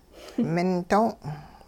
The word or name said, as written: ming do'ong